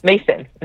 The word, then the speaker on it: Macyn: And then, is it Mason? – Mason. Mm-hmm. Yeah. – Not spelled like Mason. It's Macyn, just to confuse the rest of us.